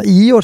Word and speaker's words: Igor: Ior säger de i Ukraina.